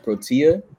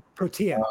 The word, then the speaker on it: Protea: Your latest album, the pronunciation, is it Protea? How do you pronounce it? – I looked it up on Google. It's pronounced Protea. – Protea.